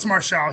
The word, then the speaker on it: Marshall: I always thought it was QT Marshall, but. – No, it's Marshall. He's been, I mean, maybe that's the way he just pronounces, but when he was in Ring of Honor, it was Marshall.